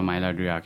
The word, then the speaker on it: Maillard: So you're going through a Maillard reaction. I always butcher the word, but it's M-A-I-L-L-A-R-D.